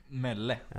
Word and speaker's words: Melle: Jag läste Jan Melle, men jag måste en liten brasklapp för uttalet. Är det Mell eller Melle? – Melle. – Ja, Jan Melle.